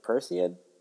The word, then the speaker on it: Perseid: The uh, the Percy Perseid, I believe it's pronounced meteor showers tonight, like the peak of it, but it's supposed to linger on through the weekend.